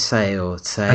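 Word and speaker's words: Tse: The guy who wrote the script for Watchmen, a guy called, I can't pronounce his name, it's Alex, but then it's T-S-E. I don't know if that's Say or something. – I think it's Say.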